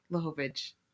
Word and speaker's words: Lachowicz: Lachowicz.